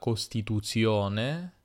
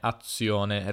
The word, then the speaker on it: costituzione: tutte queste parole che si scrivono con una sola z, una sola z, ma in realtà eh, vengono pronunciate con una doppia di fatto, costituzione, azione, reazione, non costituzione, azione, reazione.